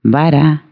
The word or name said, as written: queimar